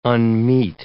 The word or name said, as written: unmeet